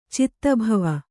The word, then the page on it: ಚಿತ್ತಭವ: Kannada